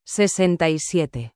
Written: 67

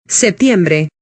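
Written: septiembre

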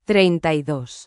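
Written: treinta y dos

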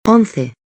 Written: 11

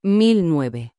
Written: 1009

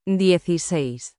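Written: dieciséis

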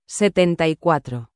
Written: 74

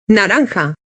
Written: naranja

